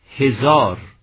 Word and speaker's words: هزار: هزار، اگر چه تلفظ درست هزار هزار است، هم امروز نیز در خراسان بزرگ آن سوی مرز هزار تلفظ می کنند. ریشه پهلوی آن نیز همین گونه است. اما ما برای آن که در فارسی معیار امروز تمیز بدهیم هزار رو با هزار، یعنی عدد رو با بلبل و هزار دستان، به آن هزار میگوییم و به عدد هزار.